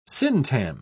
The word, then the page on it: syntagm: British English